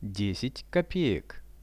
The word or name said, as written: 10 kopeek